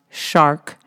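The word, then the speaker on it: Chark: There are some interesting names as usual, including a C-H-A-R-K pronounced shark and a beer.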